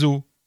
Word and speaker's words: os: Le cas est plus grave pour os, qui, malgré une orthographe qui reste inchangée du singulier au pluriel, change de prononciation quand ils sont plusieurs. Ce n'est pas en rongeant un os que l'on évitera d'avoir la peau sur les os.